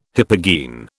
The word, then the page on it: hypogene: British English